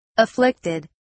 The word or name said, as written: afflicted